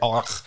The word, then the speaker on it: Ulrich: and he pronounces Ulrich so weird. Ulrich. – Ulrich. No, he does it right. That's the German pronunciation.